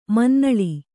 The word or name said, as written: ಮುನ್ನುಲಿ